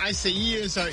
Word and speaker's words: Isaias: It's Isaias. You got to put some flavor on it. It's like Isaias. But do you know that 14 hurricanes or names of hurricanes that start with the letter I have been retired.